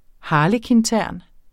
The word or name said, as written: harlekintern